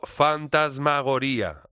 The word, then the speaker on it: Fantasmagoría: que lleva por título Fantasmagoría, ahí con el acento en la I.